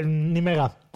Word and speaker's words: Nijmegen: Nijmegen.